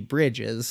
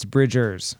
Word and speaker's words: Bridgers: But he also did refer to Phoebe Bridgers as Phoebe Bridges, so I don't know if that... – Dude, I looked two different places, and it's Bridgers.